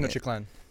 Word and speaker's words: Tenochtitlan: So in Mexico, there's this society called Tenochtitlan. I'm sure I'm mispronouncing Tenochtitlan. – There you go.